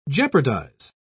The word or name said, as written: jeopardize